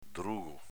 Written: drugo

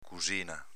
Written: cusina

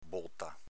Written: bota

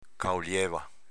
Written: caolieva

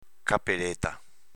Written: capeleta

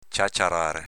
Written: ciaciarare

